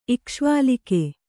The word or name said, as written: ಇಕ್ಷ್ವಾಲಿಕೆ